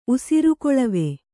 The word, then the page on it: ಉಸಿರುಕೊಳವೆ: usirukoḷave